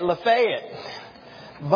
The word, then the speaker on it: Lafayette: Now, unfortunately, evidently they say it wrong up there. They should say it Lafayette, but since they are Northerners, bless their hearts. And so they moved here from Lafayette, Indiana.